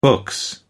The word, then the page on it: books: British English